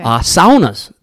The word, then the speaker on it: sauna: Saunas. – Yes. See, it's just like my husband and I. – That's the proper pronunciation, by the way. Saunas. Sauna. Yes.